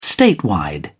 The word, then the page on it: statewide: British English